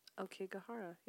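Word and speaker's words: Aokigahara: But there's an A in it. – Okigahara. Yeah. That's how I've heard it pronounced.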